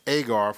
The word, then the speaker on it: Hagar: In the Old Testament, you would see the H, but this is spelling it, converting it, okay? H is silent. It says, for this Hagar,